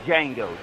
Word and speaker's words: Django: But I don't know if nobody said it was saying my movie that I can watch over and over is Django, The D is Silent.